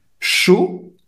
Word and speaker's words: chaud: chaud A-U, se pronuncia O, ela falou corretamente.